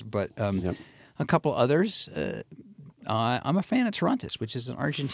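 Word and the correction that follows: Torrontés: From Argentina, actually, in Argentina, they pronounce it Torontes.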